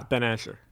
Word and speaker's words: Ben Asher: And Asher Levi. Is that how you say it? – Nah, Ben Asher.